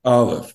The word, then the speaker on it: Aleph: Aleph, which is silent. – Which has no sound. – Yes. – But it's still vocalized in Hebrew because all letters in Hebrew have a sound. There really are no silent letters as we understand them. – Right. – Because that makes no sense in the Hebrew language.